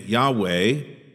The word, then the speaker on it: Yahweh: He is also called by the name Jehovah or Yehovah, some pronounce the Hebrew name Yahweh, and it is often translated as Lord with all uppercase letters in our English Bibles.